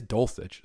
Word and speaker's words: Dulcich: I always feel like I pronounce it wrong, but it is Dulcich.